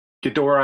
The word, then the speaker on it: Ghidorah: And then along those lines, so here's Red Dragon gonna make like a, what is it, Ghidorah?